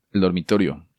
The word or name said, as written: El Dormitorio